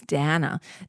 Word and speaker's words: Dana: Over on CNN, Dana Bash and Aaron Burnett, or Dana Bash, sorry, it's not Dana, it's Dana.